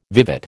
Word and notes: vivat: American English

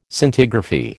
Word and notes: Scintigraphy: American English

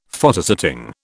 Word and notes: Photosetting: British English

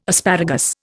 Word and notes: asparagus: Indian

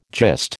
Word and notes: gist: American English

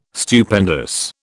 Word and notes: Stupendous: British English

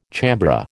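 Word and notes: chambré: American English

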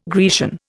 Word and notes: Grecian: Indian

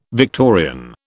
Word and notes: Victorian: British English

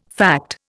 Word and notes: fact: Indian

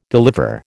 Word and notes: deliver: American English

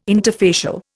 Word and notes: interfacial: Indian

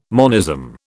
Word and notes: monism: British English

